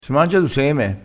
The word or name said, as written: sema